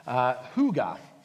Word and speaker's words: hygge: And this morning I want to talk, as Mark mentioned, about the Danish word hygge. Spelled H-Y-G-G-E, if you're wondering how you spell that, and I don't know why it's pronounced that way, it just is.